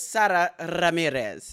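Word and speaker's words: Sara Ramírez: Because I know, listen, because I know the Anglo-Saxon pronunciation is Sara Ramirez, but I think I got it right. It's Sara Ramirez. Did I get that correct? – You really rolled that R,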